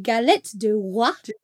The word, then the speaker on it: galette des rois: It is a galette du roi, which is king's cake in French.